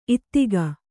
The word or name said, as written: ಇದಕೋ